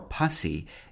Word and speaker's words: pursy: The other pursy, which can be pronounced pursy or pussy, dates from the 15th century and can mean short-winded, especially because of corpulence or simply fat.